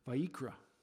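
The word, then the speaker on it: Vayikra (ויקרא): In Hebrew, the word Leviticus is pronounced Vayikra.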